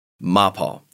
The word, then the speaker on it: MAPAL: MAPAL is an acronym, actually. It stands for Machine and Precision Tooling Aalen, which is the town in Germany that it's at. So the pronunciation would be MAPAL. But most people here say MAPAL.